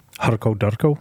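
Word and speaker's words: hurkle-durkle: But how would you say it? Like, how would a Scottish person say that? – Say it again. – Hurkle Durkle. – Harko Darko. – That's really how you would. – Wow. – Well, you gotta roll your R's.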